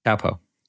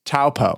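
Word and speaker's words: Taupō: Is it pronounced Taupo? – Taupo. – Taupo.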